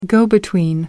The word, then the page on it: go-between: American English